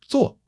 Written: zuo4

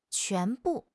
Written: quan2 bu4

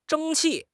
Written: 争气

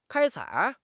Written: 开采

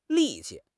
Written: li4 qi5

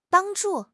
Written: bang1 zhu4

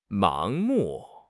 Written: 盲目

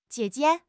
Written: jie3 jie5

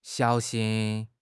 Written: xiao3 xin1

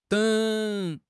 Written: deng3

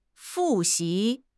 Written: fu4 xi2

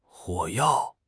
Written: 火药

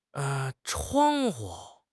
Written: chuang1 hu5

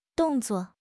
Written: dong4 zuo4

